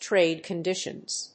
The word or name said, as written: trade conditions